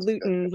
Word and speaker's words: Lootens: There's an S on the end. – Lootens.